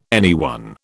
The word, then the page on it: anyone: British English